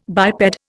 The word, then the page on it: biped: Indian